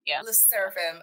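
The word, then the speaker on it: LE SSERAFIM: I always say this group's name wrong. Lee Seraphim. – Le Seraphim. – Okay.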